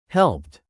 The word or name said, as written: helped